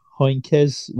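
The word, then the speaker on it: Heynckes: I'm going to butcher his name. Is it like, yep, Heinkes won it in 2013 by Munich? – I'll allow it.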